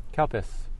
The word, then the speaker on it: Calpis: Kalpis. Okay. You want Kalpis and umami gohan. – Now I know a lot of you are thinking, did I hear what I thought I heard? But it's spelled C-A-L-P-I-S.